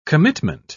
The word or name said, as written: commitment